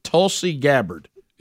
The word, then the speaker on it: Tulsi Gabbard: I would say it Tulsi Gabbard. Is that correct? – That's it. – Is Tulsi Gabbard right? – Yeah. – So tell me the Tulsi Gabbard story.